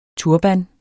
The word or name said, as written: turban